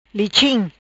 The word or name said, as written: Lichim